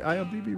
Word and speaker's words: IMDb: Did I say it right? IMDb, right? Yeah.